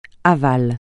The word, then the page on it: aval: francais